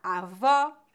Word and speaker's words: avó: Quando pronunciamos o fechado, nossa boca fica mais fechada. Avô, bolo.